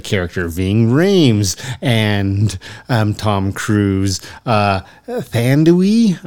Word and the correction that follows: Thandie: I don't know if I'm per- I'm butchering that name. I'm, I'm sure Newton, um, who plays oh, Nadia. Thandie, Thandie, Thandie Newton.